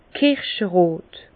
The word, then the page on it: kirscherot: Bas-Rhin; Herrlisheim